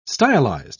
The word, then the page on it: stylised: British English